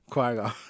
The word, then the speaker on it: Qui-Gon: He had to kill Qui-Gon. Did I say that right? – Yeah. – Qui-Gon. Dang, damn it. I'm going to get it right. I promise you. Qui-Gon.